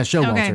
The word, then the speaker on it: Showalter: Okay, it was directed by Michael Showalter. Did I say that right? Lord. – Yeah, Showalter. Okay. Yeah, you got it.